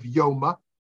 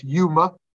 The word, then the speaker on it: Yoma: It says over here in the Talmud of Yoma, sometimes pronounced Yuma,